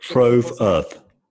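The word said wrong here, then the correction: Tov Earth: Tove Earth. – How do you spell that? – E-O-V, which means good in Hebrew.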